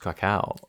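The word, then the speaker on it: Kraków: My family were from Krakow. – It's pronounced Krakow.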